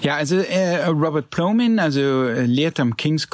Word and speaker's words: Plomin: Ähm, du hast dich mit einem Wissenschaftler auseinandergesetzt, Robert Plomin heißt der habe ich das richtig ausgesprochen? – Ja, ja. Robert Plomin, ja, ja.